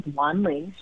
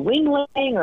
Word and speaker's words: Wan Ling: She's an immigrant from Taiwan, so her name is spelled W-A-N-L-I-N-G. And people would say things like, to Wing Lang or Wang Long, or one time I even remember Wendy, and it was just like pretty horrifying and shocking that people wouldn't even try to pronounce it even phonetically, like the letters they were using were all wrong.